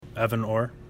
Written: Orr

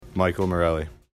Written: Morelli

